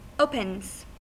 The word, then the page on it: opens: American English